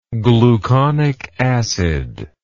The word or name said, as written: gluconic acid